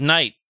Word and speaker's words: knight: And it's like, hey, did you know that knife has a silent K at the front? So does knight.